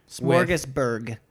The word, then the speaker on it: Smorgasburg: We're going to head to the Los Angeles Smorgasbord Festival. – That is not how you say it. – I hate the word smorgasbord. – That's not what it's called. – It's the LA Smorgasbord. – Smorgasburg.